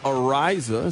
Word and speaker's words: Araiza: The Chiefs are signing former Bills punter Matt Araiza, is how you pronounce his last name.